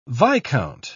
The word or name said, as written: viscount